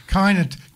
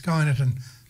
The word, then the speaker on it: Kyneton: How do you pronounce it? Is it Cainton? Caneaton? Caneaton? – Sorry. – Caneaton. Caneaton? Cainton. K-E-Y-N-E-T-O-N. Cainton. Caneaton. – Yeah, okay. Kyneton. – Kyneton? – Yes, Kyneton.